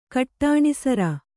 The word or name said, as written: ಕಟ್ಟಾಣಿಸರ